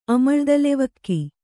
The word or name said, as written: ಅಮಳ್ದಲೆವಕ್ಕಿ